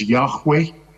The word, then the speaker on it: Yahweh: We have this strange collection of letters written J-H-W-H, pronounced often as Yahweh,